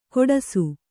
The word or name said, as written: ಖೋಡಾಸು